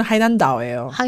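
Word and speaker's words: 하이난다오 (海南岛): 원래는 중국어로는 하이난다오예요. 다오가 섬이라는 뜻인데요.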